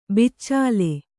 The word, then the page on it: ಬಚ್ಚಲು: baccalu